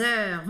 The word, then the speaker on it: heures: Les heures. Vamos começar com essa pronúncia, né? Heures. Ó, biquinho assim, ó. Er, er, er.